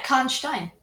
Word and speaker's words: Karnstein: The pronunciation of the name, Stein is a Jewish person. Stein is Jewish. Stein is German. Am I right? – Yeah, Karnstein. That's correct. – Yes.